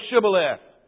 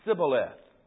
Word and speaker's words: Shibboleth: If he said no, then they would say to him, then say Shibboleth. And he would say Sibboleth. Notice the difference. For he could not pronounce it right.